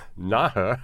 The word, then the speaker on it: Nahe: Nahe region. – That's right. That's N-A-H-E, folks. – It's hard to pronounce.